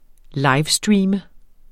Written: livestream

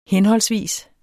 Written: henholdsvis

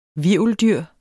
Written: hvirveldyr

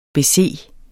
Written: bese

